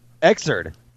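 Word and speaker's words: Xrd: What is it? – Exerd. – Exerd? – They actually say it in the announcement where, like, they say Guilty Gear Exerd.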